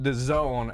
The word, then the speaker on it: DAZN: It was on, they want to call it The Zone, and I'm, fuck that, right? I refuse to call it that. It's not spelled The Zone, it's spelled D-A-Z-N, so I'm calling it Dazn. – Dazn. – It's either cool. D-A-Z-N or Dazn.